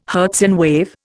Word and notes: Hertzian wave: Indian

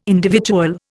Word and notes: individual: Indian

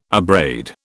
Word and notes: abrade: British English

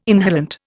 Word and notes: inhalant: Indian

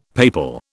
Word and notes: papal: British English

